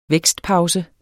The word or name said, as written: vækstpause